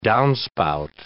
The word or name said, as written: downspout